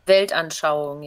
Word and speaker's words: Weltanschauung: What's the German word for it? Weltanschauung, right? – Weltanschauung, yeah.